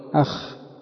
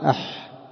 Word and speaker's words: أح: طيب قال ابن منظور رحمه الله يقولون عند الحرقة واللذع من الحرارة المميضة يقولون أخ بالخاء المعجمة، والعرب تنطق بها بالحاء المغفلة، المغفلة يعني المهملة، يقال أح.